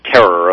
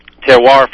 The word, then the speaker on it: Terroir: So it was Hearth first, which everyone pronounced Hearth, and then it was Insieme, which was butchered up the wazoo, and now Terroir, which is going to become Terror, of course. But Terroir, from the Latin root terra firma, it literally means soil.